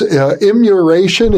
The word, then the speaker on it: immuration: I think it's immuration. Am I pronouncing it correctly? – Yeah, I made that up.